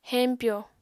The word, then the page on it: heñpyo: Apatani